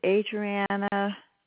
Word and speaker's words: Adriana: My first and last name is Adriana Domino. – Okay, is Adriana spelled A-D-R-I-A-N-N-A? – No, I'll spell it. It's A-D-R-I-A-N-A.